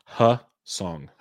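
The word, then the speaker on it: Hyeseong: I never get the pronunciation right. Hyesung Kim. What is it? – Hyesung. – Hyesung Kim. Mm-hmm.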